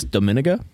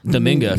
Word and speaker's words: Dominga: I think it's Dominica. – Dominica or Dominga? – Dominga, for sure. Kay. It's definitely Dominga. There's not an I in between the N and the G.